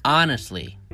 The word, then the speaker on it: honestly: Number one, honestly. Honestly. Do you hear it? The T is silent.